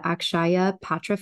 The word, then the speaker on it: Akshaya Patra: the Akshaya Patra Foundation.